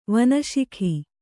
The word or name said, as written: ವನಶಿಖಿ